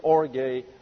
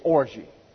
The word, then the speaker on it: orge: For those of you over here, it's orge. For those over here, it's orgy. O-R-G-E. And you bring it into the English, it's orgy. But the Greek is orge, always a hard G, and this is an eta, not an epsilon, so it gets an A sound. But when it comes over here, everything gets messed up.